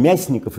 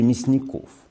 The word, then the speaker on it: Мясников: А, теперь, я не знаю, он мясников или мясников? А тот, который... для, для врача ударение очень важно. Если мы будем считать, что это доктор, а, то, наверное, Мясников.